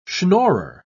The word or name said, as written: schnorrer